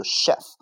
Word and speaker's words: chef: 下一个 chef，c h e f 这个词的发音读 chef，它的意思叫厨子啊，大厨。